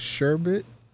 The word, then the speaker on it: sherbet: But the weed strain is called sherbet. I've been calling it sherbert. And come to find out, I was saying it correctly the whole time.